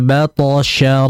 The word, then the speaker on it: بطش: Pertama, di ayat nomor 12 dijelaskan lagi pengucapan makhraj huruf shin. Inna batasya rabbika lasyadid. Jadi tadi kurang nyebar sh-nya. Batasya sh itu.